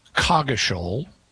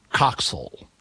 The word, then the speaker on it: Coggeshall: And according to what I was able to find, there are two modern English pronunciations in England for this name. One of them is it's pronounced Coggeshall. – Okay. – The other is it's pronounced Coxall. – Oh, I wouldn't have got either of those. – Yeah. Also, there's an American English pronunciation, which is Coggeshall, different than Coggeshall, the British pronunciation.